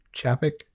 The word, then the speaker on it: Chapek: Is it Chapek? – Sure. – Sure. Chapek. Chapek.